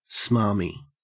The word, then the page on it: smarmy: Australian English